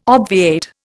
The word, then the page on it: obviate: Indian